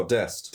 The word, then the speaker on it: Dest: Talking of USA, the one player I was quite impressed with in their team was that Dost, I don't know how you pronounce it, the right back. – Oh, Dest. – Dest, yeah, I say, yeah.